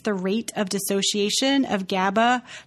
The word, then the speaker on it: GABA: So it's good to know that that is actually GABA. And Jed said it correctly, that it exerts its primary sedative and hypnotic effects via interaction with the GABA receptor.